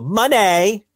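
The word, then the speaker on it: Moné: So you listen to the song, it's like dun dun dun, and then you hear the former Sasha go, Money. So you have to say it like that, Rich. Money.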